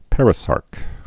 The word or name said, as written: perisarc